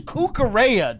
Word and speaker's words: Cucurella: It's Cucurella, dog.